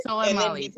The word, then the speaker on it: Soleimani: Soleimani, thank you.